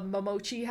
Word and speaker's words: Momochi: Then I watched The Demon Prince of Momochi House. – Let's go. You said it right the first time.